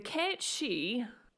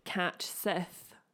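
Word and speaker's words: Cat Sìth: So, the Cat She, which is the Gaelic, it literally looks like it's written like Cat Sith. But that's not how you pronounce it.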